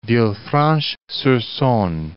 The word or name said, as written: Villefranche-sur-Saone